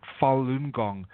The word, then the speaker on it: Falun Gong: And with Falun Gong, this spiritual mind and body practice, basically pronounced Falun Gong,